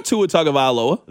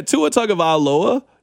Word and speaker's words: Tagovailoa: It took me a little bit, but I got Tua Tagovailoa.